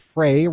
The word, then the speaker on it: Frey: I got this text from Colby Frey, pronounced Frey, rhymes with day.